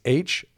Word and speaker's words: H'Doubler: And then the first dance major was created by, her name was Margaret, and I think I'm saying this right, Hadoubler. It's H'Doubler.